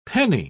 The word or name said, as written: penny